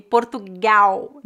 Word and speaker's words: Portugal: O L é pronunciado como U. Então, Brasil, não Brasil, e Portugal, não Portugal. É claro que em final de palavras essa pronúncia é bem fraca, né?